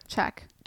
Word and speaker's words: Czech: How do you say C-Z-E-C-H, that country. – Czech Republic. – I always want to put in the like Z. Yeah, so Czech, it's like Czech.